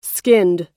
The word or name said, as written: skinned